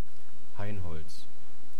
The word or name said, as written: hainholz